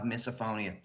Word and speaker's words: misophonia: Tell me, what is, I hope I pronounce this right, misophonia, is that what it is? – Misophonia, yeah.